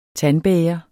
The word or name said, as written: tandbæger